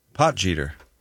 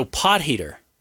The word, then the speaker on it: Potgieter: Potgieter. – He's South African, and it's crucial to use the he sound, a soft G, rather than a hard G. So pot heater.